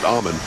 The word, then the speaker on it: almond: No, because I didn't say almond. I said almond without the L.